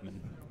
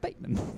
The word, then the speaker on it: Batman: Shit, it's, it's apparently pronounced Bateman.